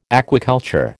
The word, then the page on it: aquaculture: American English